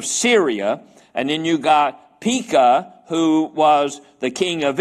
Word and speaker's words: Pekah: and Pekah, yeah, that's a long E, isn't it? Pekah, the son of Remaliah, king of Israel.